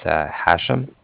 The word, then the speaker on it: Hashem: It's Hashem. – Spell that, please. – Yeah, no problem. It's H-A-S-H-E-M, as in mother at the end.